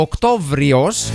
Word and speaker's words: Οκτώβριος: Και ο Οκτώβριος, θα συνεχίζω να το τονίζω αυτό το βου στη μέση χωρίς μου, ο Οκτώβριος μετακινήθηκε στη 10η θέση, αλλά διατήρησε την παλιά του ονομασία.